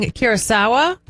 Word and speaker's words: Curaçao: All right, we've got the Ivory Coast facing Curaçao. Did I say it right? – Mm-hmm.